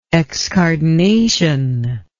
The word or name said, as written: excardination